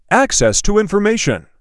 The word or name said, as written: Access to information